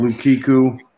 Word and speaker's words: Lukaku: Lukaku. Lukaku. – Yeah. I don't even know who that is. I really don't even know who that is. I'm sorry. – Lukiku.